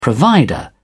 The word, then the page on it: provider: British English